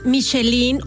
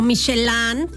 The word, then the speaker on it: Michelin: ¿Ustedes saben saben lo que es la guía Michelin o Michelin, como se quiera pronunciar, porque en francés es Michelin, pero en inglés es Michelin.